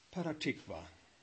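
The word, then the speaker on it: Petah Tikva (פתח תקווה): In Hebrew this is pronounced Petah Tikva.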